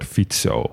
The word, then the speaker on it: Fico: Maar een C in het Slovaak spreek je uit als TS. Dus vandaar Robert Fico.